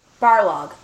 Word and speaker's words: Barlog: I'm still concerned because Corey Balrog, Barlog. I always say his name wrong because I think of Balrog from Street Fighter.